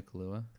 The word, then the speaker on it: Kaluuya: Daniel Kaluuya. – Kaluuya.